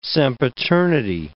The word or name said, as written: sempiternity